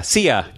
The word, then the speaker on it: Sia: You got Tim Robertson, Mike Schmidt, Kirsten, how do you say her last name? Sia. That's right. Sia. I got it right. Like Sia.